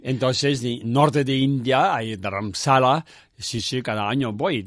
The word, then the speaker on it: Dharamsala: Y, entonces se creó en, a, ver si lo digo bien, Dharmasa se llama. Dharamsala.